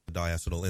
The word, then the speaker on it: diacetyl: Diacetyl in the final product. – That's not how you say it. – Diacetyl. That's how you say it, Russ.